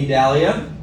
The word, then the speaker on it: Idalia: If you didn't know how to pronounce it, it's Idalia, Hurricane Idalia, which stuck out of there.